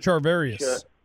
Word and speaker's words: Charvarius: Charvarius.